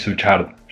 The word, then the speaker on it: Suchard: Entonces yo creo que pasa el Suchar ahí de O el Suchar, no sé cómo se pronuncia tampoco. Yo tampoco. Pero bueno, pasa el, es Suchard. Suchard.